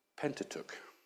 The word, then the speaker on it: Pentateuch: Genesis, Exodus, Leviticus, Numbers, and Deuteronomy, which are collectively known as, if I can pronounce this right, if there's any Greek scholars, you'll tell me, Pentateuch. Oh, I got that right, thank you.